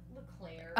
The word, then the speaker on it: Leclerc: And we have Katie Leclerc, which is how you say her last name, here to talk about it with us. – Leclerc. – Oh gosh. – Like a chocolate eclair.